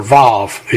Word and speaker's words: Vav: Vav. It is V in the original text, as shown as the first letter of each verse, and is pronounced V as in vine.